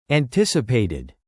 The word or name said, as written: anticipated